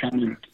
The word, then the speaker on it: Chanute: The French name Octave Chanute. Chanute. And in fact, I believe there's... Chanute. – Okay. – And it's pronounced Chanute.